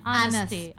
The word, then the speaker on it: honesty: Jadi memang ada huruf awalnya itu H ya, pakai H, tapi itu dibacanya honesty.